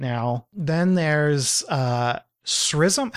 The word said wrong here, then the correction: XRISM: How do we even pronounce this? XRISM. – CRISM. – CRISM. There we go.